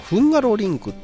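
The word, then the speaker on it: Hungaroring: 向こうではフンガロリンクってね、発音されるみたいですけど。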